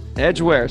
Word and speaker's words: Edgware: The London suburb of Edgware. Is that how you say it? – Yeah. – Edgware.